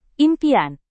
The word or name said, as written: impian